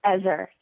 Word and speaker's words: Ezer: You pronounce it Ezer? – Ezer, E-Z-E-R. Mm-hmm. Right, that's the Hebrew word, and it means benevolent and strong.